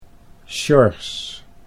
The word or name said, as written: Sjors